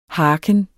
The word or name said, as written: harken